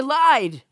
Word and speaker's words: elide: It's elied. – Elied or elide? – It's elide, actually.